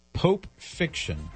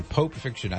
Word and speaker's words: Pope Fiction: And I should spell it for people who might not have heard it right. Pope Fiction. P-O-P-E. Pope Fiction.